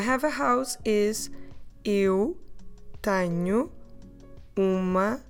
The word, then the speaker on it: tenho: So the first person, I have, we say eu tenho. Eu tenho, tenho, tenho, eu tenho. How do we spell it? T-E-N-H-O.